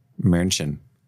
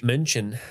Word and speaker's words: München: München. That's how they pronounce it off Deutsch. – München. – München.